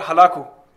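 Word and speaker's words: Hulagu: So this Genghis Khan, he had appointed his grandson, whose name was Hulugu, I think that's how you say it in English correctly, Hulugu. In Urdu you call it Halaku.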